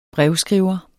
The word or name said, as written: brevskriver